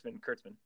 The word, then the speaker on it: Kurtzman: Am I saying that right? Kurtzman? – Yeah, Kurtzman.